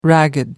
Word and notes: ragged: American English

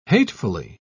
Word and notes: hatefully: British English

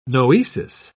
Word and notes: noesis: British English

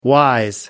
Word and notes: wise: American English

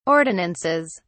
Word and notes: ordinances: British English

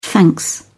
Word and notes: thanks: British English

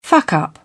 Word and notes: fuck up: British English

